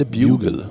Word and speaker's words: bugle: Ja, jag kan berätta att låten heter Boogie Woogie Buggle Boy. Eller den heter inte Buggle, den heter Bugle.